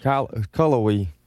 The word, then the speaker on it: Cullowhee: C-U-L-L-O-W-H-E-E. – You're going to have to go a little slower than that. Go ahead. What now? – Cullowhee. – C-U-L-L-O-W-H-E-E. – That sounds right, Cullowhee.